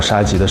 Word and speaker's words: 沙棘: 给这个顾客介绍沙棘的时候，很多人把沙棘这两个字还念错，念成沙拉，还以为沙棘是咱们内蒙的一个野菜。